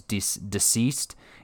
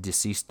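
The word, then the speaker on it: DCeased: And same goes for this other series that I'm really enjoying, which is Deceased. It's spelled DC East, Deceased that way, because it's the DC Universe dying.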